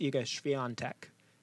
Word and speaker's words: Iga Świątek: My pick for the women, stick with me here, is a young upstart from Poland, Iga Świątek. – Tell us why. – Well, first of all, I butchered her name. I'm sorry. It's Iga Świątek. It is not spelled that way, but languages do things differently, as we're learning from Holland.